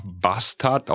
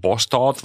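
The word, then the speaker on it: Båstad: Ähm, Lars hat mir empfohlen, nach Bastard, auch wieder falsch ausgesprochen, Bostorf wahrscheinlich in Schweden, ein tolles, tolles Turnier an der Küste, ähm, dass ich da mal hinfahren soll.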